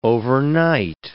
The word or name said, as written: overnight